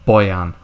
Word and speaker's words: Bojan: I think there's a, because I know in Spanish, like at school, I think you have to pronounce J's as like Hue, so it's like Boyan or something.